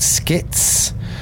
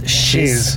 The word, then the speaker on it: Schiz: I'm doing a show called Skits, which is spelled S-C-H-I-Z, which everyone instantly pronounces as shiz.